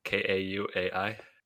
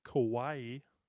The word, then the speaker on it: Kauai: K A U A I. – I believe that's pronounced Kauai.